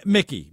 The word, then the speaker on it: Miki: is it Mickey? – How you say it, it's uh, Mickey, Mickey, Mickey, Mickey okay.